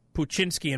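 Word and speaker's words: Poochinski: Peter Boyle played a gruff, sarcastic cop in Puchinsky, which is spelled P-O-O-C-H, Inski. Puchinsky.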